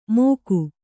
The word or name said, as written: ಮೋಕು